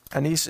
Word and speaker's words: Anise: Anise is A-N-I-S-E. You might pronounce it Anise.